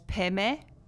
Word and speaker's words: Peme: And then for like Om Mani Padme Hum, they often pronounce Padme as Peme, like P-M-E. No, P-E-M-E. Peme. And then Hum, which is H-U-M, they sometimes pronounce Hung, H-U-N-G.